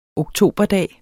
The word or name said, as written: oktoberdag